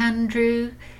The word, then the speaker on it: Aindriu: I thought I was somewhere in Europe at first, but I think you pronounce it Andrew, but it's spelled A-I-N-D-R-I-U, and I think that's an Irish spelling, but at first I interpreted it as Greek.